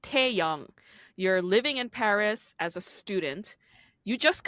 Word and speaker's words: Paris: even in France, they don't pronounce it Paris. They say Paris. They don't pronounce the S.